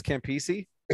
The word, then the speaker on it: Campisi: Am I saying that right? Campusy. – I'm not sure. I think it's Campisi. – Campisi sounds right.